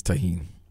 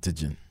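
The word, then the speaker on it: Tajín: It is Tajin. It's not Tajin because the J is silent.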